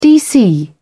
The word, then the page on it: dc: British English